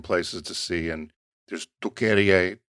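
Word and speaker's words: Türkiye: And the pronunciation goes to Turkeye.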